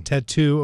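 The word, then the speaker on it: t.A.T.u.: It's Russia with, I think it's Tattoo is how you say. – Tattoo.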